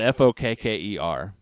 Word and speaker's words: Fokker: He shot down an enemy Fokker. – That's funny. – It's spelled Fokker. – Sure it is. – Is that how you would say it? – Absolutely. – That's how, if you follow English.